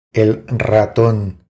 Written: El raton

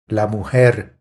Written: La mujer

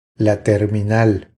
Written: La terminal

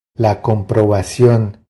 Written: La comprobacion

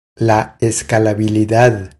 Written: La escalabilidad

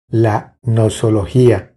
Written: La nosologia

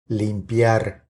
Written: Limpiar

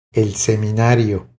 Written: El seminario

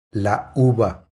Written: La uva

